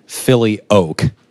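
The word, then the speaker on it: Filioque: took a few years of Latin in high school, says it's pronounced Philly Oak.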